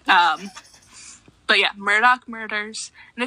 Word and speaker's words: Murdaugh: It is pronounced Murdoch.